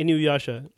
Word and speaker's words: Inuyasha: Inuyasha.